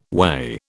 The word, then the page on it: way: British English